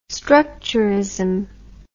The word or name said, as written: structurism